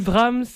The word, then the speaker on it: Brahms: Brahms, Rhapsody numéro 2, op. 79. Est-ce que je l'ai bien prononcé? – Ça va.